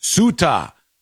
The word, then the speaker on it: Ceuta: It's pronounced Ceuta, in case you see it somewhere, but it's a C-E-U-T-A.